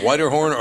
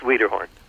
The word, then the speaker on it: Wiederhorn: Jon, you say your name Whiterhorn or Wiederhorn? – Uh, it's Wiederhorn. – It is Wiederhorn.